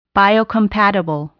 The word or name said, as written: biocompatible